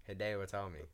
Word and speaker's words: Hideo Itami: Hideo Itami.